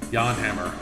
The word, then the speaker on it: Jan Hammer: Jan Hammer.